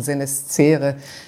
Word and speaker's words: senescere: Und ich habe mal nachgeschaut, das kommt aus dem Lateinischen senescere. Ich gehöre noch zu denen, die haben das C wie C ausgesprochen und nicht wie K.